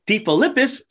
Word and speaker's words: DeFilippis: Today, I'm joined by Christine DeFilippis of PotFit Studio.